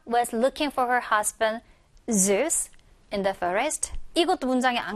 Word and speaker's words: Zeus: 우리는 제우스라고 하지만 영어식으로 읽을 때는 이제 Zeus에 가깝게 발음이 되어 들릴 거예요.